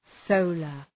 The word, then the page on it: solar: ‘səʋlər